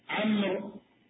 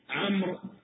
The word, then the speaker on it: عمرو: أما التمييز اللفظي فبالحركات، حتى نميز عمرو عن عمر لفظا بالحركات، نقول عمرو بإسكان الميم، عمرو بفتح العين وإسكان الميم، عمرو.